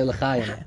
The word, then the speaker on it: L'chaim: Yeah, we say L'chaim.